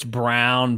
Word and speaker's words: Braun: What is the right way to say it? – It's Brown, but yeah. – It's Braun, like Ryan Braun.